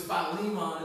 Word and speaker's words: Philemon: I called it that for so many years of my life until someone graciously took me aside and said, "Hey, man, that's not how you say that." And I was like, "Oh, really?" I mean, I was like, no one ever corrected me before. But it's Philemon is how you say it.